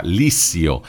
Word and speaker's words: liscio: che in Romagna si pronuncia Lissio, nato a Gatteo il 15 agosto del 37, morto ieri mattina all'ospedale Bufalini di Cesena, dove era ricoverato dal 2 marzo.